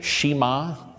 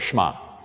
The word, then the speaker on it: Shema: Almost pronounce it like the E's not there. Pronounce it like the E's not there. Shema. And that's what it is.